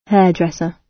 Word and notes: hairdresser: British English

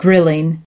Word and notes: grilling: American English

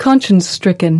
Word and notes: conscience-stricken: American English

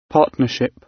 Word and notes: partnership: British English

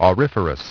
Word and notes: auriferous: British English

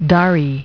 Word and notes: dari: British English

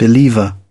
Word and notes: believer: British English

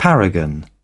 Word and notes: paragon: British English

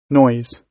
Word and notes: noise: British English

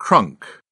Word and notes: crunk: British English

